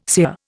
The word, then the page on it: sear: Indian